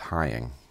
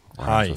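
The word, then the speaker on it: Heying: Heather and I should say her last name is pronounced Hying. – Hying. – Flying.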